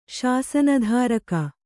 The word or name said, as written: ಶಾಸನಧಾರಕ